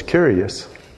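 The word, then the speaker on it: curious (κύριος): It's spelled with a K, but it's curious. K-U-R-I-O-U-S, curious.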